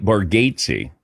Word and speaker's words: Bargatze: But then when we met him in Vegas, or Tahoe, he said, no, it's, he goes, my family actually mispronounces it, but it's Nate Bargatze.